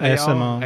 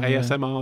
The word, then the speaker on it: ASMR: So there is a, how do you say it, AMSR? SMAR? – ASMR. – ASMR, yeah.